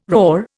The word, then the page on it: raw: Indian